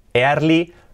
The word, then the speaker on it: early: Lo primero, la pronunciación, early, la R, early, early. La R y la L, dos letras claves, early, early.